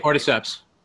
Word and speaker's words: cordyceps: And it's about the benefits of taking cordyceps. Am I pronouncing that right? – Cordyceps.